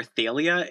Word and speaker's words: Thalia: I pronounce everything mostly as they do in the audiobooks, except for Talia, because they call her Thalia in the audiobooks, and I hate that.